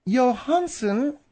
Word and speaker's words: Johansson: Now, according to French radio RTL, Johansson, which is the French pronunciation, or Johansson, filed a lawsuit against J.C.